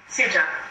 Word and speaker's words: Ceuta: How's that pronounced? – Ceuta.